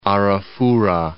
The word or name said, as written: Arafura Sea